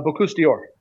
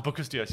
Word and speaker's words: Bocuse d'Or: But is Bocuse d'Or? – Denmark. The Bocuse d'Or. – Ah, Bocuse d'Or.